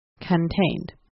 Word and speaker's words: contained: You want to pronounce the beginning of the word C-O-N, not as con, but as cun, C-U-N, cun, contained.